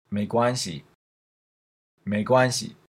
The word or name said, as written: meiguanxi